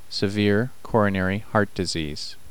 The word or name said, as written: severe coronary heart disease